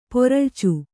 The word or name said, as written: ಪೊರಳ್ಚು